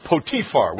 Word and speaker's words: Potiphar: his name is actually pronounced Potiphar. We call him Potiphar.